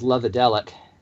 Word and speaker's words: Lovedelic: I mean, I always pronounce it as Lovedelic. – You're probably more correct, but Love to Leak is how my brain always parses it, so... – Just remember, it rhymes with psychedelic.